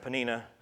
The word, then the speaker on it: Peninnah: Um, and I did look it up. That is pronounced Peninnah. I kept thinking Panera, then I wanted some coffee.